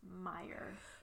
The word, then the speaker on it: Meyer: I say Mayer. – Okay. – But that's... I think I know that it's Meyer.